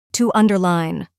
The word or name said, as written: to underline